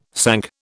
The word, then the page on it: sank: British English